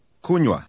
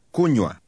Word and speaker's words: Kunywa: Kunywa. Nywa. Ku. Kunywa. Kunywa.